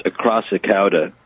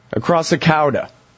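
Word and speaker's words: Acrassicauda: It's pronounced Crassicada. – Crassicada. – Exactly.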